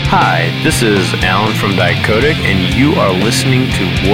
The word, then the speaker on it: Dicodec: Dicodec.